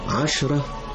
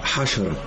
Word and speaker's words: عشرة: عشرة حشرة. نعم، لا تنطق العين صحيحة فتنزلق العين من مخرجها الصحيح إلى الحاء، فأسمع كلمة عشرة حشرة، وهناك فرق كبير بين العشرة والحشرة.